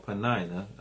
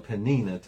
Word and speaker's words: Penina: And Elkanah had two wives, Hannah and then Penina or Penina, depending upon whatever is correct. I've heard it pronounced both ways, but let's call it Penina tonight.